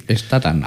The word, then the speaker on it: Statham: de Statman, no sé qué se pronuncia bien, Jason Statman. ¿Está bien dicho o no? O puede Statham, ser. Algo así. – Statham. – Ah. Statham. Así que los invito a que vean esas películas.